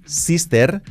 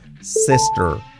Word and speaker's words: sister: Sister, mal pronunciado, cuidado, pronunciación correcta, sister. ¿Cómo es ese sonido? Sister, sister, sister. Ese sonido, repetidlo, sister.